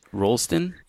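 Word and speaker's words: Roulston: Ash Rolston? – Yeah, R-O-U-L-S-T-O-N, I'm pretty sure.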